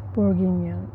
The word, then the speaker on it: Bourguignon: This is Louis Jadot, Bourguignon. Did I say that right? – Bourguignon, yeah. Oh.